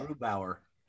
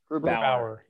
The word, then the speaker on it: Grubauer: I think it's uh, Grubauer. – Grubauer. – Yeah. Grubauer. Grubauer. – Yeah, I keep saying it wrong.